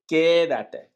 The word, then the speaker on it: quédate: Porque el acento es en la E. ¿Sí? – El A, E. Sí, no, pero es que tú pronuncias quédate. Es quédate. – Ok, quédate. – Con E, no con E.